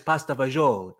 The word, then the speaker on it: pasta fagioli: So like today I had a nice pasta fagioli. Did I say that right? – You did very good.